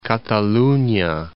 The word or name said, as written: Cataluna